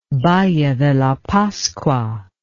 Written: Valle de la Pascua

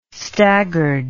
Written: staggard

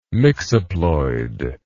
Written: mixoploid

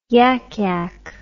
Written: yak yak

